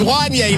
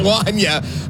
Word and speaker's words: Wanya: You still didn't say it right, Catherine. It's Wanya, not Wanya.